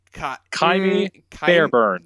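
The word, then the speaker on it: Ka'imi Fairbairn: His name is K-A-apostrophe-I-M-I, and then F-A-R-B-A-I-R-N. Kaimi Fairbairn. – And then, that is how you pronounce it.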